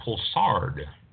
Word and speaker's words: Poulsard: Next one, Pulsard. Or Pulsar is French, so maybe the D is not pronounced. P-O-U-L-S-A-R-D.